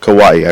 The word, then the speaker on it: kawaii: This whole kawaii, I pronounced that correctly, did yep.